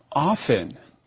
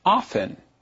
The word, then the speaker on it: often: At LTE Studios, we don't want people to get confused, so we always say it the same way, often. – Right. Often is spelled O-F-T-E-N. So you can either pronounce it often, like we do on the show, or it can be pronounced often, with the T sound.